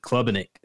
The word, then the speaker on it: Klubnik: I'm really excited to see what he can do, especially with Klubnik.